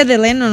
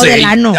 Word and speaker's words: Delano: Pero se dice Delano, ¿no? – Ah, yo no sé. – No, Delano. – Ah, yo no sé. – Se escribe así, digo, porque a mí me corrigieron un montón de veces.